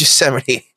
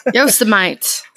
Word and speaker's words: Yosemite: We're not even from this planet and we know it's Yosemite. – Yeah. – Yosemite.